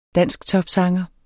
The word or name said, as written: dansktopsanger